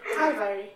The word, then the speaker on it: Calvary: Calvary.